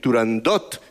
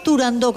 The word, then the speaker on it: Turandot: pues no había que decir Turandot, sino que, a pesar de que tuviera una T... – Pues no haberse la puesto, oye, Turandot. Yo también te digo. ¿No quieres que la pronuncien? No la pongas. ¿No? – ¿Pero qué estamos hablando? – Pues de no ponerla, ya está, que la quiten. – Qué bueno. – Si vemos Turandó con acento en la O, todo el mundo dirá Turandó.